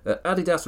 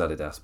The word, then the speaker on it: Adidas: I've always said it as Adidas, but I'm assuming every American would be shouting at me going Adidas. It's like, no, suck it. You're getting my common English way of pronouncing it.